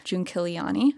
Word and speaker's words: Giunchigliani: Uh, we got word from both Attorney General Adam Laxalt and Clark County Commissioner Chris Giunchigliani. I think I pronounced that correctly, yes.